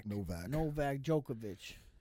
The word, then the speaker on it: Djokovic: Yo, Djokovic, man. Yep. Was it, did I say it right? – Novak Djokovic.